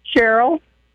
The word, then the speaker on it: Sherrill: Cheryl. – How do you spell that, Angie? – S-H-E-R-R-I-L-L.